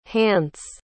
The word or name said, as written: hance